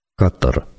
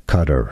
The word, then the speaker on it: Qatar: So let's start with Arabic. The pronunciation is Qatar. – Qatar. – That's your pronunciation in Qatar. Arabic. In English, it's more generally pronounced as Qatar, with a stress on the second syllable, but some say it as Qatar. as well. Or, more often, the Americans say it as cutter.